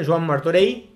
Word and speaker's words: Joan Martorell: Y él tenía el asesoramiento de un, ah, célebre arquitecto, que era Joan eh, Martorell, eh, bien catalán también, un nombre bien catalán, Joan Martorell, sería la pronuncia correcta, por lo menos del apellido.